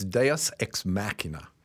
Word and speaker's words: Deus Ex Machina: We better get this straight. It's Deus Ex Machina.